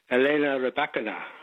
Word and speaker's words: Elena Rybakina: Well, I hope I get the pronunciation right. It's something like Elena Rybakina. – Yep, sounds pretty good to me.